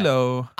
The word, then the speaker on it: Lilo: And Lilo and Lilo and Stitch. Why am I mispronouncing everything? – Lilo.